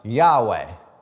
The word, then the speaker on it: Yahweh: And nobody's 100% sure how this is pronounced, but we know that it's connected to Echwe, right? This I am. And most scholars believe that it's pronounced Yahweh, which is He is.